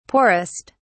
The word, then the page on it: poorest: British English